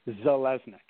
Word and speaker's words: Zeleznick: It is pronounced Zeleznick.